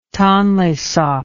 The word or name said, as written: tonle sap